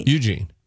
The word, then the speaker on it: Eugene: Do you say Eugene or Eugene? – Eugene. – You. Eugene. – Eugene. No, Eugene. Yeah, emphasis on the U.